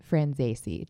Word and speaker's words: Franzese: Okay, so please welcome Daniel, aka Danny Franzese.